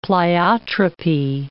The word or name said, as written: pleiotropy